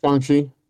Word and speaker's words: Shang-Chi: With the success of Shang-Chi, is that how it's pronounced? I've never actually heard it pronounced. – Shang-Chi.